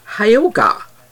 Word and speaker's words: hygge: And about culture and lifestyle, hygge, this is spelled H-Y-G-G-E. Danes are known for the concept of hygge, but it's pronounced hyoga.